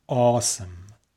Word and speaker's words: awesome: And it was awesome. آسم معمولا به همین شکل تلفظ میکنن.